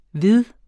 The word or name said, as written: vid